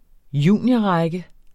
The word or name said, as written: juniorrække